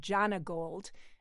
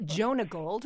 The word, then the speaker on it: Jonagold: So I was today years old when I found out that apple is pronounced Jonagold and not Jonagold, which is what I always thought it was.